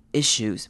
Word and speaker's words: issues: Breathing 这个字注意 ea 长母音 e， breathing，然后这边的 th 呢，我们是发有声的 th，要发 the 的声音， breathing issues。 Issues 呢这个字也是一样，它是 i 开头，而且是短母音 i，所以记得不要念成 issue，我们要念成 issue。